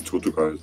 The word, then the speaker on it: Tortugal: Ich weiß selber nicht, wie ich es aussprechen soll. Ich würde es Tortugal sagen. – Tortugal. Äh, auch so einen äh, lateinamerikanischen Akzent. Mit so einem gerollten, eher so.